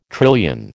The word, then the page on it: trillion: American English